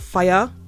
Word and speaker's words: Fyre: Check out Fyre, and it's spelled F-Y-R-E, Fyre. That's how it's spelled.